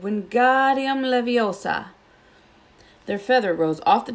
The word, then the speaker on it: Wingardium Leviosa: Wingardium Leviosa, he shouted, waving his long arms like windmill. You're saying it wrong, Harry heard Hermione snap. It's Wingardium Leviosa. Make the ga nice and long.